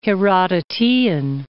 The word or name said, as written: Herodotean